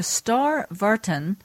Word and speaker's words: Starre Vartan: Well, I'm really glad we finally get a chance to speak to Star Vartan.